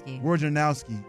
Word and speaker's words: Wojnarowski: Wojnarowski.